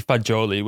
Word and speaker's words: fagioli: So it might be fagioli, which is pasta with beans. – Is that how you say it? – Yeah, so fagioli is.